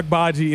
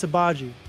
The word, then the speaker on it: Agbaji: Ochai Agbaji, NBA Rookie of the Year next year, right? – It's Agbaji. – Agbaji? – Agbaji. – Okay, I've been saying his name wrong.